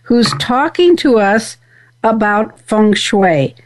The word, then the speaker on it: feng shui: R.D., am I pronouncing that correctly? – Yes, you are pronouncing it correctly. Actually, there are many dialects in saying feng shui, and so this dialect is kind of more from the Mandarin Chinese dialect, which is the most used dialect or acknowledged in China.